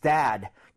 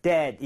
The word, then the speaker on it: dad: 그래서 그렇게 하시는 게 아니라 dead 이게 아니라 얘는 턱을 많이 내려주셔야 됩니다. 이런 느낌이에요. Dad.